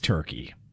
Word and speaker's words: Turkey: I'm not going to pronounce it Turkey. It's Turkey. I'm sorry.